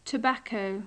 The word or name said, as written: tobacco